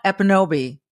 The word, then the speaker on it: Ekpunobi: Amala Epinobi. Don't pronounce the K, folks. You'll see it there, but it's silent, you know, which is kind of neat.